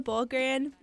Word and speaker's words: Balkaran: So it's Mia underscore Balkaran, B-A-L-K-A-R-A-N.